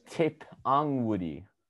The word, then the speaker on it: Tipungwuti: It's Tip Ung Woody. – Oh, couldn't pronounce like that. – Tippin. People say Tip and Woody. – Was it T-I-P-P-U-N? – T-I-P-U-N-G-W-U-T-I.